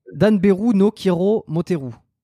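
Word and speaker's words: Danberu Nan Kiro Moteru: Alors, ah, Dan, Beru, No Kiro, Moteru.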